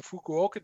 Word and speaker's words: Fukuoka: This was run in Fukuoka, Japan, as opposed to Tokyo last year.